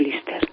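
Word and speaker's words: Líster: Lister, pronunciado Lister, que fue el general que aguantó a los nacionales en Madrid, bueno, y al final, claro, estaba la parte republicana, etcétera, etcétera.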